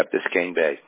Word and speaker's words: Biscayne Bay: Yeah, Biscayne Bay.